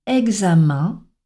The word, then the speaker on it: examen: Alors, EN se prononce souvent en, mais dans quelques mots, on l'entendra prononcer un. C'est le cas dans les mots examen, agenda ou encore référendum.